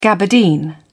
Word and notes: gabardine: British English

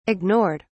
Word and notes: ignored: British English

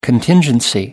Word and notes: contingency: American English

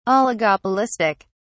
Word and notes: Oligopolistic: British English

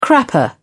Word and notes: crapper: British English

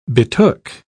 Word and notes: betook: British English

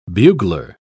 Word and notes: bugler: British English

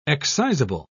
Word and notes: excisable: British English

